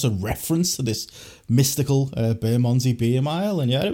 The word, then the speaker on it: Bermondsey: I went on the Bermondsey Beer Mile. I hadn't even heard of it. – Bermondsey, pronouncing it wrong. Bermondsey Beer Mile.